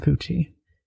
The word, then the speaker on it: Fucci: The next day, 14-year-old Aiden Fucci, is that how I'm going to pronounce it? – That's how you pronounce it, Aiden Fucci.